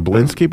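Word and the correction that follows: Bielski: brothers? – Belsky brothers.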